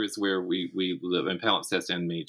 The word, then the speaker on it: Palimpsest: And Palimpsest, for all the trouble people have saying it and writing it down, it literally is exactly as it sounds and pronounced exactly as it's spelled, P-A-L-I-M-P-S-E-S-T, Palimpsest.